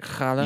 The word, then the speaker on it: challah: Challah.